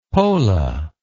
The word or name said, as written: pola